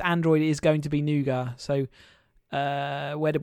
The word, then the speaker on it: Nougat: It's going to be called Nougat. – So you see Nougat and I see Nougat. – That's because that's how it's said.